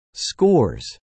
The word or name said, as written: scores